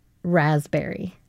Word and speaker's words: raspberry: Raspberry. – Can you spell it now? – Yeah, because it's the silent P that got me. Why does it have a silent P? – You didn't get the raspberry. – No, I got B-E-R-R-Y.